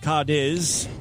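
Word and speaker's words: Cádiz: Atletico Madrid versus Cadiz. That's how I would pronounce it. Cadiz.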